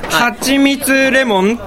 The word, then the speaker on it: はちみつレモン: あ、すいません、はちみつレモンって読んでいただけると、